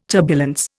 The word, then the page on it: turbulence: Indian